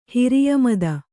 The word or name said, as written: ಹಿರಿಯಮದ